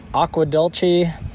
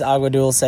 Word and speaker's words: Agua Dulce: I am currently in the town of Agua Dulce, also pronounced Aqua Dulce. Aqua Dulce. Basically, no one can pronounce this trail, not this trail, but this town correctly. But it is Agua Dulce.